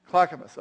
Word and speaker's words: Clackamas: Is that how you pronounce that one? Clackamas, okay.